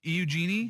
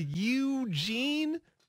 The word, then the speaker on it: Eugene: Putting too much emphasis on the E. – Eugenie? – Get the U in there. – Well, but it starts with an E. – I know it does, but it's like phone.